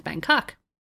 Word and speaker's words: Bangkok: 24 down, the world's most visited city, 20 million plus travelers annually, and it's Bangkok. – Why'd you say it that way? – What did I say it like? – You put a lot of emphasis on the second syllable.